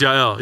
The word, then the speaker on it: Yael: It's pronounced Yael.